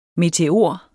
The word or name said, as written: meteor